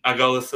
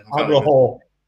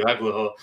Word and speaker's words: Aguilar: Nelson Aguilar, I can't say his name. – Aguilar. – Thank you, Aguilar.